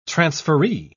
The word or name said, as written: transferee